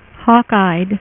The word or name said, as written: hawkeye